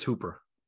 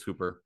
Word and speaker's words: Hooper: So replacing Goddard, I pulled Tennessee's Austin Hopper. Hooper, however you want to say his name. Is it Hooper? – I think it's Hooper. Austin Hooper. – Yeah, it's double O. It's Hooper.